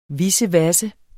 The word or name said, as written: vissevasse